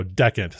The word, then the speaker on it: Dechant: so decant.